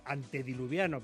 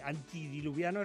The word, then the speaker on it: antediluviano: Y segundo, entérate de cómo se dice antediluviano, porque antidiluviano es contrario al diluvio. Antediluviano es anterior al diluvio.